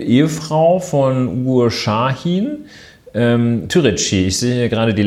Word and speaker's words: Türeci: Ja, sprich, äh, dann spricht man das so aus, ja? Ich würde da eh Türeci sagen. Ich äh, würde jedenfalls nicht Türeki sagen. Und ja, Türeki klingt auch ein bisschen komisch.